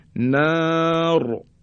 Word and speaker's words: نار: مثل نار را ساکن قبل از اون الف کشیده است الف مدیه زمانی که میخوایم را رو ساکن تلفظ بکنیم دیگه را تفخیم و درشت تلفظ میشه